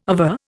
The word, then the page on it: aver: Indian